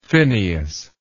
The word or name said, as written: phineus